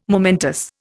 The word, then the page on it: momentous: Indian